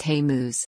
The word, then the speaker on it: Hamas: In doing so, they say they will support his music on social media, until they learn that his band is called Hamas, pronounced Hamuz.